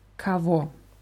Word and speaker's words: кого: Das Wort wird geschrieben mit K-O-G-O. Vier Buchstaben. Das G zwischen zwei O's wird als W ausgesprochen. Kavo.